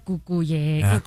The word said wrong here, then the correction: Cucurella: Ya no puedo. Cucurella.